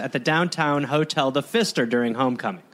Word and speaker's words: Pfister: And it's the Pfister, and it doesn't help that it has a silent P.